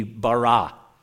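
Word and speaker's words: bara (בָּרָא): If I were to transliterate the Hebrew word or try to give it a rough pronunciation, it would simply be bara.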